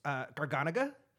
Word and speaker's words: Garganega: It's Garganega.